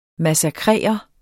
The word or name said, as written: MASSAKRERE